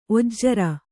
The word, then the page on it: ಒಜ್ರ: ojra; Kannada